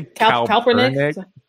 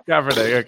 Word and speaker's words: Kaepernick: Could you say Kaepernick? – Kaepernick, sorry. – Kaepernick, okay.